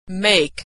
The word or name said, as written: make